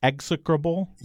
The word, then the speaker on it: execrable: You know, is it the stress is on the first syllable? Execrable? – Yes. – Yeah. – Execrable. Execrable. – Execrable. – Wow. Well, anyway, E-X-E-C-R-A-B-L-E.